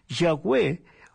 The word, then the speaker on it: Yahweh: Cuando se agregan las vocales, se pronuncia Yahweh, que en español sería Jehová. Es la traducción que se le da al español del nombre Yahvé.